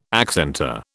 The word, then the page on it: accentor: British English